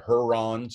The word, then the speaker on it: Herron: One pronunciation said Heron, so H-E-R-R-O-N.